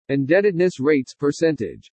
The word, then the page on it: INDEBTEDNESS RATES-PERCENTAGE: English